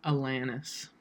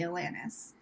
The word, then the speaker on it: Alanis: Is it Alanis or Alanis? – Alanis. – That's how I say it. I say Alanis. – I say Alanis or Alanis because I don't give a shit.